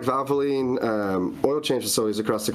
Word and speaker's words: Valvoline: Valvoline, is that right? – Valvoline, yeah.